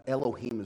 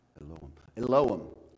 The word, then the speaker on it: Elohim: Elohim is how we pronounce it in Randolph County. If we were in Jerusalem, we would really kind of pronounce it with that Hebrew flair on it. But in the Hebrew, it would be Elohim.